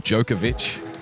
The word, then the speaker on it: Djokovic: And Novak Djokovic, best player in the world, and that is how you say it, by the way, Djokovic or Djokovic. It's been some very interesting pronunciations in the media over the last few days. But generally accepted, Novak Djokovic. Djokovic. You've got to put yourself in the mind of a Yugoslavian grandfather.